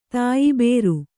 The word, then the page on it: ತಾಯ್ಬೇರು: tāybēru